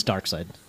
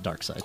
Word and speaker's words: Darkseid: See, I was, it's Darkseid. – Yeah, I always call him Darkseid. – Jack Kirby created him. Jack Kirby pronounced it Darkseid.